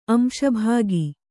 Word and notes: ಅಂಶಭಾಗಿ: Kannada

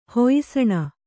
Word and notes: ಹೊಯ್ಸಣ: Kannada

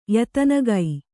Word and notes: ಯತನಗೈ: Kannada

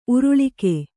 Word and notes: ಉರುಳುಕ: Kannada

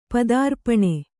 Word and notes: ಪದಾರ್ಪಣ: Kannada

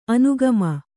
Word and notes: ಅನುಗಾಮಿ: anugāmi

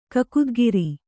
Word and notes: ಕಕುದ್ಗಿರಿ: Kannada

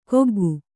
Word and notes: ಕೊಗ್ಗು: koggu

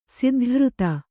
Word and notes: ಸಿದ್ಧಘೃತ: siddha ghřta

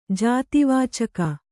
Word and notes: ಜಾತಿವಾಚಕ: jāti vācaka

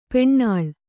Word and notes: ಪೆಣ್ಣಾಳ್: peṇṇaḷ; Kannada

